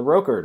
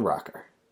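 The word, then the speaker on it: ROKR: it was always the Roker, not the Rocker. But you're going to hear Roker every time I pronounce it that way. It's the Rocker. Sorry.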